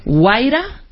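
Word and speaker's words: Wayra: Es mentor en la aceleradora, veme diciendo si lo, lo pronuncio bien. Sí. Waira. Sí. sí.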